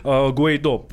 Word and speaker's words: Гуайдо: Э, Потерял ну, в общем, а, Николас Мадуро, нынешний а, президент, а, якобы был отправлен в отставку, да, соответственно, нынешним лидером оппозиции Гуайдо, и так его фамилия именно произносится, Гуайдо назвал себя главой государства, президентом.